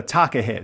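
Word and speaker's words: takahē: So, anyone out there listening on the gargle, it's go to find the takahe. There's an accent on the E,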